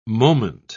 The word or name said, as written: moment